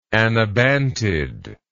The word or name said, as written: anabantid